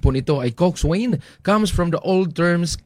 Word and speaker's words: coxswain: In modern rowing, the coxswain is the strategist, navigator, motivator, and field commander of the crew, mga kapuwersa. Ang pagbasa po ng coxswain, pero pag pronounce po nito ay Coxswain.